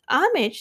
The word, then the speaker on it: homage: Homage 是一个名词，H-O-M-A-G-E，它是 silent H，所以念 homage，意思是尊敬、敬意。